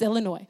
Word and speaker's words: Illinois: And so Springfield, Illinois, and if you are from Illinois, you know that the S is silent.